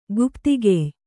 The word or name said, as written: ಗುಪ್ತಿಗೆಯ್